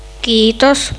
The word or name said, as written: kiitos